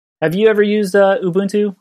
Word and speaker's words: Ubuntu: So I looked it up, and it's Ubuntu. So Ubuntu is African for,